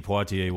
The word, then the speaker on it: Poitier: Yes, these drinks will make you blind, and then Sidney Poitier will guide you to a new life.